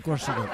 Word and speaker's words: croissant: Le croissant.